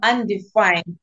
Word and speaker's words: Undefyned: I am undefined, as in the word undefined.